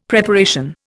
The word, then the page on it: Preparation: Indian